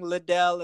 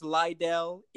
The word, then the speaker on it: Liddell: There are the things that people have been noticing, mispronouncing Liddell as Lydell, even now.